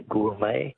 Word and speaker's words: gourmet: Words that end with a silent T. Beret is B-E-R-E-T. Which word for a connoisseur comes from an old French word meaning wine taster? – Be a gourmet. – Gourmet, yes. Correct. G-O-U-R-M-E-T.